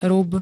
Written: rub

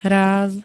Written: ráz

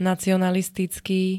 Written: nacionalistický

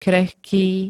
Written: krehký

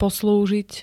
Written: poslúžiť

